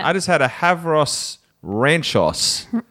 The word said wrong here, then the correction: Huevos Rancheros: Did I say that right? – Huevos Rancheros.